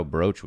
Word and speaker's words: brooch: I was really surprised at how brooch was spelled. Brooch. Never wrote that word down before. – B-R-O-O-C-H, yeah. Yes. It's not spelled how it's pronounced.